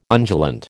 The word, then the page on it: undulant: American English